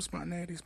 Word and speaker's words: spontaneity: If there's no, I've been saying it wrong all this time. Spontaneity. Y'all get the gist, man.